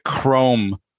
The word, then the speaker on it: Crom: And the capper was, apparently Rick Crome, Crom, I guess is the pronunciation, C-R-O-M, who we recently mentioned because he was in the cast of The Bedwetter.